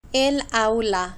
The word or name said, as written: el aula